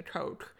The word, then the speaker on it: Koch: I don't know if that's how you say it. – K-O-C-H? – Yeah. – Koch. – Yeah. Okay, Christina Koch. I wasn't sure if it was the same as the name of the foundation.